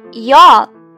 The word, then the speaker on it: your: Your，y o u r，your，你的，读这个词可以先读成 you，然后将舌头卷起来，your。有些朋友喜欢读成 your，这是不对的哦，一定要注意。